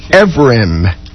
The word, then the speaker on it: Evrim: Is her pronunciation guide that came with all the propaganda? – Evrim. E-V-R-I-M.